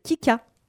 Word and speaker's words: Ki2ca: Kika, qui est une artiste parisienne qui a son propre projet, qui fait exclusivement que de la soul. C'est extrêmement bien. Je vous recommande d'aller écouter Kika. Ça s'écrit K-I-2-C-A.